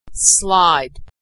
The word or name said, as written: slide